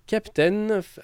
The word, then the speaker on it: KPTN: Alors on y trouve notamment l'album de Captain. Alors en fait c'est KPTN mais ça se prononce Captain.